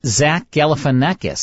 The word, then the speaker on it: Galifianakis: Yes, I've not seen it, but it's Zach Galifianakis starring in that, right? – You said that right.